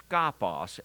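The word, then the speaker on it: skopos (σκοπός): The word is pronounced skopos, and we get our word scope from it.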